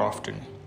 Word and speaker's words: often: So, forgetting is, is common thing, and in this clouded world of fast internet, it's happening more often or more often. T is silent.